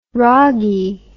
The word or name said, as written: ragee